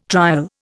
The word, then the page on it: trial: Indian